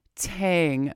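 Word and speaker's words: TANGK: I'm Anita B, and we just listened to some new music there from the British band Idles, all caps, I-D-L-E-S. The song is Pop, Pop, Pop from their album Tang. Apparently it's pronounced T-A-N-G, but it has a K at the end of it.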